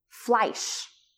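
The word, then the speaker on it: flysch: And then there was Fleisch, which is, it's spelled F-L-Y-S-C-H, I believe.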